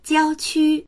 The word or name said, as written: jiao1qu1